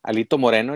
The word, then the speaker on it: Alito Moreno: Alito Moreno, el dirigente nacional del Partido Revolucionario Institucional.